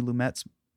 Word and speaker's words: Lumet: Sidney Lumet's? – Yeah.